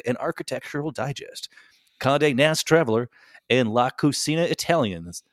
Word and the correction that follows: Italiana: It's Italiana.